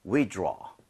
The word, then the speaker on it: withdraw: 발음할 때는 draw가 아니고요. aw라는 스펠링은 보통은 이 모양을 벌려주셔야 돼요. – 네. – So, 이렇게 한번 따라해 보세요. withdraw.